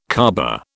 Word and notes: Caaba: British English

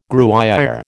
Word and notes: Gruyère: American English

